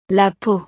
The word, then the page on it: la peau: frances